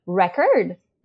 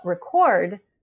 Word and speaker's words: record: If you look at a word like record, that's the noun, but record, that's the verb. So the spelling is the same, but the where the stress falls, and as a consequence, how the syllables are pronounced differs depending on what part of speech the word is.